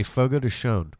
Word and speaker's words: Fogo de Chão: There's an N. They say Fogo de Chão.